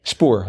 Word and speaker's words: spoor: Spoor, S-P, is that how you say it? – Spoor, uh-huh.